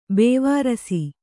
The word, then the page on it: ಬೇವಾರ್ಸಿ: Kannada